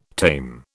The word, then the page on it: tame: British English